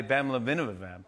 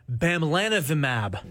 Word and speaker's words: bamlanivimab: Antibody bamlanivimab. – The drug is called bamlanivimab.